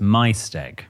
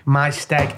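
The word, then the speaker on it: Maesteg: and I can confirm that pronounced the steg part correctly, however the May, M-A-E part, is pronounced my, so it's my steg. – My steg, I know it is, my granddad was from there, yes.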